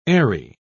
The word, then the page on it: eyry: British English